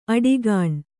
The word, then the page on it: ಅಡಿಗಾಣ್: aḍigāṇ